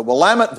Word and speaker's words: Willamette: And he was serving with a regiment in, I'm going to say this right for Harold's sake, the Willamette Valley in Washington State, which is the source of the Pinot Noir that Harold drinks.